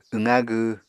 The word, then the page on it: wqa gw: Apatani